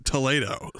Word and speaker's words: Toledo: I got yelled at when I said Toledo, and he said, it's Toledo. – And they got that guy to come out, and he said, this is how you pronounce it. – This is how you pronounce the restaurant on top of Coronado Springs. It is Toledo's.